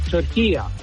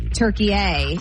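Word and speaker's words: Türkiye: At the end is a short A. It's not a long A. In the Arabic language, it's called turkiya. So you could say turkiya. – You and I are making it a hard A. Turkey A, and it's turkiya.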